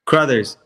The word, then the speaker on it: Crouthers: Crowthers, close.